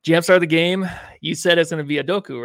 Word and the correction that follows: Udoka: Yeah, how do you say his name? – Azubuke. Udoka. Udoka, my bad.